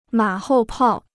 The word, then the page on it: 马后炮: mǎ hòu pào